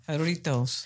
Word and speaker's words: Jarritos: Uh, Doritos. I don't know. – Oh, Jarritos.